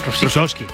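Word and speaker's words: Rusowsky: Que fíjate que Karol G ficha a Rusicki. Se dice así, ¿no? – Rusovsky. – Ah, Rusovsky.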